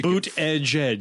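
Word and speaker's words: Buttigieg: Just to help you out, because Insider actually has published a 2020 pronunciation guide. – Wow. – News you boot edge. – Boot edge.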